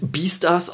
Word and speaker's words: Beastars: ist halt ich glaube, es wird Beastars ausgesprochen. Ich glaube, kommt einfach von Beast und dann ja. – Star, Beastar. Genau,